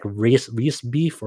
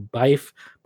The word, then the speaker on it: Riesbyfe: It's like Ria, R-I-E-S-B-Y-F-E. That's her name. It's like Reese Beef or Bife, but some people just call her Roast Beef to just for simplicity's sake.